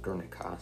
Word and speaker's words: Guernica: Okay, it looks like it's pronounced Guernica, so I've been saying it wrong this whole time.